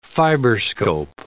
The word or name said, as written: fiberscope